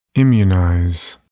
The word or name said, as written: immunize